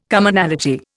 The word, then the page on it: Commonality: Indian